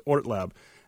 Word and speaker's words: Hort Lab: They actually don't pronounce the H, but it's Hort Lab.